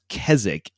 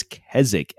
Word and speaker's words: Keswick: I got to tell you, like, it's pronounced Keswick. I understand it looks like Keswick. It's Keswick.